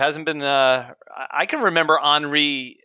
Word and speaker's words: Henri: Well, even with the common names like this year, Henry's back, but of course we all know it's not pronounced Henry. – That's true. – I didn't know that. Boy, it's a good thing you guys had me on, or else I would have been mispronouncing that. – You see, it ends in an I, so we're going with Henri.